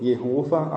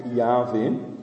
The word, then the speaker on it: Jahwe: Einige sprechen den Jehova aus, aber wahrscheinlich ist die richtige Aussprache Jahwe.